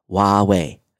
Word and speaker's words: Huawei: He says, you pronounce what I've been pronouncing Huawei is Huawei, Huawei. And he says, here's the links for all of us geeks. So it's Huawei.